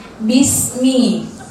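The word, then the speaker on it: bismi (بِسْمِ): Tapi kalau di ba-nya bismi ini harokatnya kasroh. Jadi perlu kita tekankan ketika kita baca bismi itu i karena kasroh.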